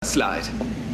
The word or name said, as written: slide